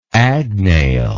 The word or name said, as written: agnail